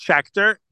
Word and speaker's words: Schachter: My last name is, it's Schachter.